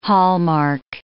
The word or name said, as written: hallmark